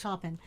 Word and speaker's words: Taupin: Taupin.